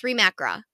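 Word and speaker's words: Reem Acra: I always pronounce it wrong. Reem Akra. It's Reem Akra.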